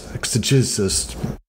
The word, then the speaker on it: exegesis: It challenges exegesis. Please, someone, anyone, pronounce that for me. – Exegesis. – Thank you.